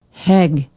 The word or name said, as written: hegg